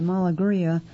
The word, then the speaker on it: Malegria: Okay, Malagria, the artist is spelled M-A-L-E-G-R-I-A, Reina, R-E-Y-N-A, Tropical, T-R-O-P-I-C-A-L.